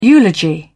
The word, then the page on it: eulogy: British English